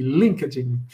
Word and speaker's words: LinkedIn: Eu gosto da sua pronúncia, eu falo LinkedIn, mas sua pronúncia em inglês é chique, LinkedIn.